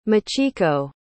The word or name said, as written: michiko